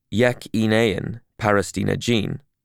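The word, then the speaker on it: Yekîneyên Parastina Jin: The YPJ, or Yekineyen Parastina Jin, pronounced Yekineyen Parastina Jin for women, and the YPG, or Yekineyen Parastina Gel, pronounced Yekineyen Parastina Gel for men.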